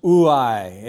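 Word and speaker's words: uai (οὐαί): In the original, the word is pronounced uai.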